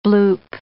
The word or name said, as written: bloop